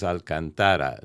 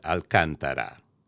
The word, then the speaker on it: Alcantara: For the rest of this test recording, I'll read a summary of the Alcantara material text from my original NTH-100 review. The cushion's material is Alcantara, not Alcantara.